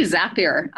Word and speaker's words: Zapier: Zapier or Zapier. I never know how to pronounce it. – It's actually Zapier. I learned a while back. It rhymes with happier.